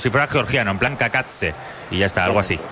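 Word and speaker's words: Cacace: La caída de Liberato Kakatse. He tenido que buscar cómo se pronunciaba este futbolista para no cometer errores. Y según la IA es Kakatse. – Kakatse.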